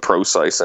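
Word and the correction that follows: Prosise: in there or something. ProCC, I mean to say. I didn't mean to offend the family saying it wrong.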